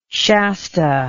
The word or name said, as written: shasta